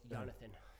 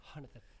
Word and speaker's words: Jonathan: Oh, it's a soft J. It's Jonathan. – Jonathan.